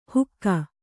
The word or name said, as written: ಹುಕ್ಕಾ